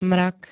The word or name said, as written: mrak